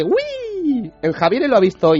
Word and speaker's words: Wii: ¡Wii! – En fin. – ¡Falta bien! – La mierda, Nintendo. – En palabras de la compañía japonesa Nintendo, se pronuncia como la palabra inglesa Wii, que significa nosotros.